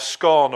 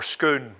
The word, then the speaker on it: Scone: You may have heard of the Stone of Scone or Scone or Scoon, whichever way you choose to pronounce it, which upon which the kings of Scotland were crowned.